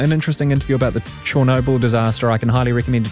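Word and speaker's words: Chornobyl: I'm going to have to retrain myself to do the right pronunciation now. Chernobyl. Ukrainian pronunciation. Chernobyl was tops.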